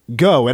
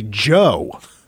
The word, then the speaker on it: Geaux: If you put the, of course, the G and then the E-A-U-X, the E-A-U-X is not pronounced with the G, go. It actually should be Joe. – Right. – And go in French is not G-E-A-U-X. It's actually like A-L-L-E-R.